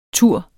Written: ture

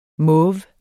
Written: mauve

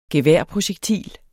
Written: geværprojektil

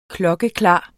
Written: klokkeklar

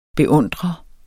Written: beundrer

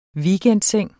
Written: WEEKENDSENG

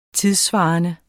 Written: tidssvarende

